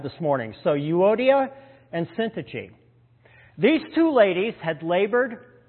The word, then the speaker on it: Syntyche: But now he zooms into a very specific instance of disunity within the church, speaking to two women by name, Euodia, and I looked up the pronunciation, Syntyche, or you can resort to Syntyche, but I'm going to go with Syntyche.